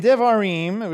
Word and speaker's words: Devarim: In Devarim, which is how you say that in Hebrew, chapter 16, you have a remembrance of the three great pilgrimage festivals, the third one being Sukkot.